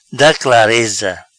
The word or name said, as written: Da Clareza